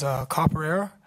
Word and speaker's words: Capoeira: and I could never pronounce this word, but it's Capoeira.